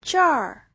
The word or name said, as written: zher4